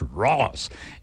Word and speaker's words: Ross: I said his name wrong a while ago, but Josh Rose is what I was saying. It's Josh Ross.